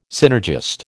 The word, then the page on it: synergist: American English